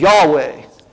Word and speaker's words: Yahweh: Scholars have come along and ours and added alpha and A in here and an E in here. And so we normally pronounce that as Yahweh.